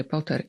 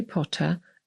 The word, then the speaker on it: Potter: Amerykanin wymówi nazwisko Harry'ego Pottera jako Potter. Ja będę dzisiaj mówić z akcentem brytyjskim, bo autorka jest Brytyjką i wszyscy aktorzy występujący w filmach również są albo Brytyjczykami, albo Irlandczykami, więc wymowa Potter pojawia się dużo częściej.